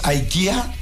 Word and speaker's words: IKEA: En sueco, IKEA se pronuncia IKE con acento agudo A. – IKE. – IKE. – IKE. Entonces, IKE en Suecia y seguramente en Europa, al ser IKE, en Estados Unidos, IKEA, y en México muchos decimos IKEA.